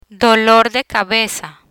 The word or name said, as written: dolor de cabeza